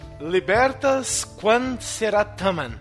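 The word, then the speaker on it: Libertas quae sera tamen: Libertas quand será taman.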